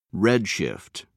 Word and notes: redshift: American English

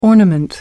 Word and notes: ornament: American English

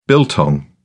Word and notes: biltong: British English

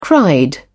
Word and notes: cried: British English

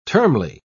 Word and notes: termly: British English

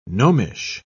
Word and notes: gnomish: British English

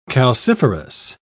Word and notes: calciferous: British English

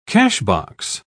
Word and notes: cashbox: British English

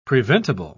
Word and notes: preventible: British English

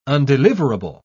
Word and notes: undeliverable: British English

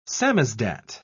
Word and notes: samizdat: British English

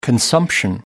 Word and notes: consumption: American English

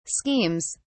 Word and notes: schemes: British English

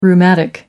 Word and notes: rheumatic: British English

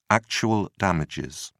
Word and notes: actual damages: British English

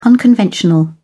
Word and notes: unconventional: British English

